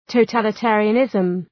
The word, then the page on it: totalitarianism: təʋ,tælı’teərıə,nızm